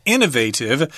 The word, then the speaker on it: innovative: This one is in Texas, of course, the Longhorns in Austin, Texas, and this is an innovative tool. You might hear British people say innovative. I've heard that pronunciation before, but the American pronunciation is innovative, which means it's new, it's novel, it hasn't been done before, it's original, and it's advanced.